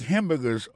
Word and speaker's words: hamburger: I make hamburgers all day. Pour ça concerne les prononciations, on prend hamburgers à son mot qui est trois syllabes, il dit hamburger, hamburger, on mange à bon, tu manges moins hamburger à mort.